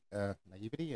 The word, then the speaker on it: naivety: But you know what was crazy, and I guess maybe it's just my naivety. Naivety? Am I pronouncing it right? Yeah, naivety. I had no idea that Ford GT Falcon was the same car in Mad Max.